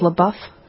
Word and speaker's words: LaBeouf: Maybe not so pronounced. LaBeouf, I believe.